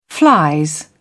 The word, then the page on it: flies: British English